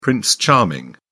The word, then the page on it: prince charming: British English